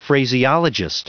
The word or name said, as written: phraseologist